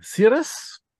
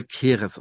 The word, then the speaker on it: Ceres: Es ist also jetzt wirklich bei mir eingezogen, Keres oder wie sie dann es nennen, Ceres. Also das original wird es wohl Keres ausgesprochen. Ich würde sagen, ich würde es eigentlich Keres nennen, aber. Ja, aber im Englischen wird das nicht so ausgesprochen.